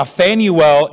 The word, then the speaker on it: Phanuel: Now, Phanuel is the Greek pronunciation of the Hebrew name Penuel.